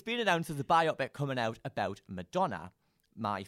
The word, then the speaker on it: biopic: The matter at hand is whether it's pronounced biopic or biopic. I've since discovered that Viola Davis pronounces it biopic. So if you're listening and you don't think it's biopic, take it up with Viola Davis.